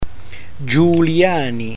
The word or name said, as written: giuliani